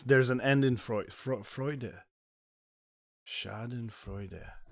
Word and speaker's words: Schadenfreude: it's pronounced Schadenfreude. – That is correct. – Schadenfreude is the word.